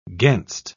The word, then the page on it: gainst: British English